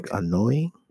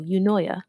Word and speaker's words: Eunoia: You said Eunoia? – Eunoia. It's spelled... – annoying? – No, Eunoia. – Eunoia. – It's spelled Eunoia.